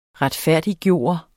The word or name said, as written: retfærdiggøre